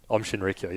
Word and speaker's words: Aum Shinrikyo: Is that how you say it? – Om Shinrikyo, yeah.